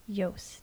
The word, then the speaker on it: Joost: It's just think of toast with a Y. – Joost. – X. – Very good. – That, what a mind. – Merci. – Very good. Brilliant. – Joost.